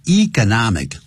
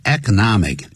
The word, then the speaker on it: economic: 由于什么关系呢 ?because of the economic, 或是 economic, 两个发音都可以 ,economic, economic, 都可以。